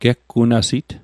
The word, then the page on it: geggunasit: Mi'gmaq